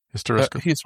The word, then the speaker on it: Hysteroscope: Is it Hysteroscope? Am I saying that correctly? – Hysteroscope. – Hysteroscope. Thank you.